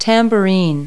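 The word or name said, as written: tambourine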